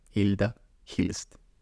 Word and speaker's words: Hilda Hilst: Es muy difícil de pronunciar en portugués. Porque no es un nombre común del portugués, es mm, de otra lengua, proveniente de otra lengua. No estoy seguro de cuál, pero en Brasil lo pronuncian así y por eso estoy intentando pronunciarlo así. Vale. En español sería Hilda Hilst.